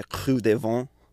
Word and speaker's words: Creux des Vents: It's, I'll say it like a French, they call it the Creux des Vents.